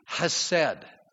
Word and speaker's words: hesed: And there's a word in the Hebrew, it's hesed. And we would spell it in English, H-E-S-E-D, hesed.